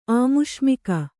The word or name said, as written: ಆಮುಷ್ಮಿಕ